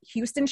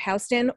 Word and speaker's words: Houston: So I said Houston Street instead of Houston off of the FDR.